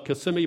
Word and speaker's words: Kissimmee: Kissimmee by day, but kiss me by night is what they used to say.